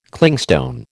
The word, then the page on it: Clingstone: American English